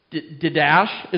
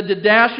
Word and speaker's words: Didache: I had my computer pronounce it for me last night because I knew I wasn't going to say D-I-D-A-C-H-E. Didache is what it... I think it said Didache. D-A-D-A-C-H-E.